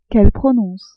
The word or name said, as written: qu'elles prononcent